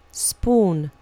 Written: spoon

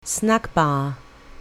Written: snack-bar

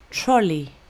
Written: trolley

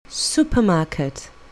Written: supermarket

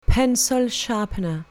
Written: pencil sharpener

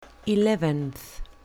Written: eleventh

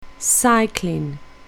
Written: cycling

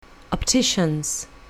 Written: optician's

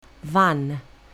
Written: van

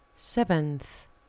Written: seventh

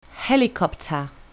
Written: helicopter

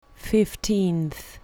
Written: fifteenth

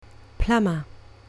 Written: plumber

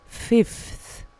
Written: fifth

